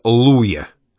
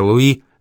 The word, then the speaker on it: Луи: «Поставщики Виктора Луя», — определила Люся. Сахаровы всегда произносили «Виктора Луи» на русский лад. Ударение, впрочем, иногда ради рифмы переносилось «Луя».